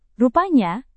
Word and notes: rupanya: indonesian

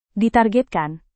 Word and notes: ditargetkan: Indonesian

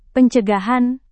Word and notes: pencegahan: Indonesian

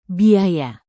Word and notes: biaya: indonesian

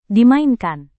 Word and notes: dimainkan: Indonesian

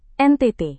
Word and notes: NTT: Indonesian

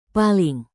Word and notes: paling: indonesian

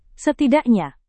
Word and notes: setidaknya: Indonesian

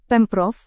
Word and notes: pemprov: Indonesian

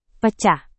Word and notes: pecah: Indonesian

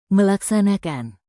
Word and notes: melaksanakan: Indonesian

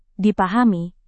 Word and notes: dipahami: indonesian

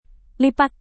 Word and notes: lipat: indonesian

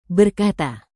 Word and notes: berkata: Indonesian

